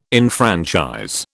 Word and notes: enfranchise: British English

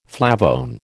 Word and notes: flavone: American English

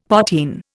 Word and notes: potheen: Indian

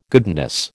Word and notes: goodness: American English

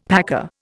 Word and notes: packer: Indian

